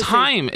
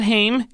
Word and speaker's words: Haim: Like, see, Kira, it's Haim. It rhymes with time. It's not Haim. It's not pronounced Haim. It's pronounced Haim.